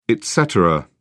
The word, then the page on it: etc: British English